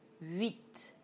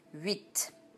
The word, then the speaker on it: huit: Oui, O-U-I. So, oui, louis, éblouir, enfouir. Now the sound oui, like in the number 8 in French. Huit. It's the only time when you will hear the T. Very few times when you pronounce the end of the word. Huit.